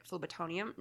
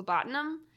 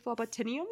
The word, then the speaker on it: phlebotinum: So you have immortality is achieved through ethically dubious forms of applied phlebotonium. Nope. Mm-hmm. Phlebotinum.